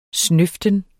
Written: snøften

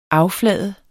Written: afflade

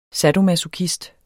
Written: sadomasochist

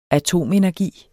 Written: atomenergi

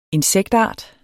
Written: INSEKTART

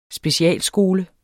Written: specialskole

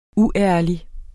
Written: uærlig

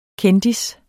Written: kendis

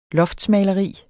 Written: loftsmaleri

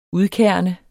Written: udkerne

